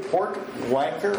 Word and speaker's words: Porwancher: I'm going to apologize right now that I'm going to butcher it, but I will do my best. Andrew Porwancher? – Porwancher. – I knew I was going to mess that up.